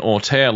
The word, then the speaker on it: auteur: Whereas if you have someone like an auteur looking at this at the end, and geez, I'm probably mispronouncing that. – I think it's one of those words you can pronounce however you want, which is kind of cool. – Auteur. – Yeah, that's how you should do it.